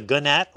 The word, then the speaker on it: gnat: N-A-T. And to play with words a little bit, if you put a silent G in front of NAT, it becomes a gnat or a gnat, which is a pest.